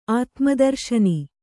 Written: ಆತ್ಮದರ್ಶನಿ

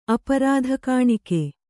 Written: ಅಪರಾಧಕಾಣಿಕೆ

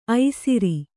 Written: ಐಸೂರಿ

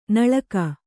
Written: ನಾಲಿಗೆ

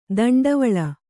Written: ದಂಡವಳ

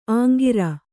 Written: ಅಂಕುರ

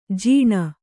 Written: ಜಣ

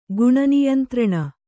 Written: ಗುಣನಿಯಂತ್ರಣ

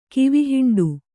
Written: ಕಿವಿಹಿಂಡು

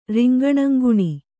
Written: ರಿಂಗಣಂಗುಣಿ

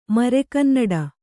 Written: ಮರೆಗನ್ನಡ

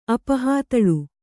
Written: ಅಪಹಾತಳು

